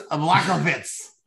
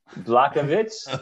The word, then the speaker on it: Blachowicz: We have Backwatch Vzik. I don't know how to say this guy's name, man. Against Textera. – I think it's Blachowicz. – Blachowicz? – Blachowicz.